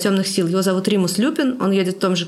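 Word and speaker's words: Lupin: Ну, тут все-таки надо сказать, что вот Римус Люпин, мы некоторое время обсуждали, где у него ударение, но решили, что так как его фамилия явно происходит от слова «люпус», которое на латыни значит «волк», то, наверное, он Люпин.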